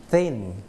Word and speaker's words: thin: เอาลิ้นออกมาข้างนอกครับ thin